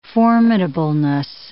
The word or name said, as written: formidableness